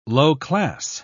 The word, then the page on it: low-class: British English